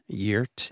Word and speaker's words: Gert: This is Gert Ingebrigtsen. Yurt. – I was wondering how you'd say that. – I don't know. My Norwegian pronunciation might not be perfect here.